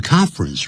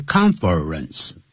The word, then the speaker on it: conference: conference room 记住不要念成 conference 啊这样要念成两个音节 conference 那个 confer 那个 er 它是不念出来